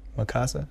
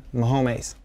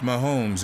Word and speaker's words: Mahomes: Mikasa? Mahomes. – Mahomes. – Pronounced Mahomes in both English and Spanish, the reigning MVP for the Kansas City Chiefs will undoubtedly be the focus of the Bolts defense in Mexico City.